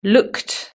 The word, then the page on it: looked: british-english